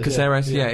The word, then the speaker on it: Cáceres: Cáceres.